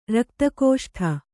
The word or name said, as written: ರಕ್ತಕೋಷ್ಠ